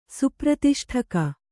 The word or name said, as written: ಸುಪ್ರತಿಷ್ಠಕ